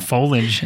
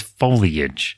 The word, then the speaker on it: foliage: It was talking about foliage at the, I'm sorry, foliage. I think I've said it wrong this whole time. It's foliage.